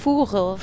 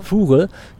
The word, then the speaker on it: Vugel: Also Patte und Vogel geschrieben, also V-U-G-E-L, ne? – Furel. – Furel, genau, der Pattevogel. Und ich habe es nur extra dann so ausgesprochen, weil ich dachte, dann wird es noch ein bisschen deutlicher, dass ich den Vogel meine.